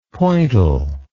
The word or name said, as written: pointal